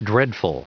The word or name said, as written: dreadful